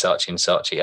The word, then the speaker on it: Saatchi & Saatchi: Did I pronounce that right? – Yeah. In the UK, it's Saatchi & Saatchi. I mean, it depends what side of the Atlantic you're on.